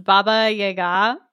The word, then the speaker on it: Baba Yaga: I also love and respect that you pronounced Baba Yaga. We just say Baba Yaga. You pronounced it like the correct way.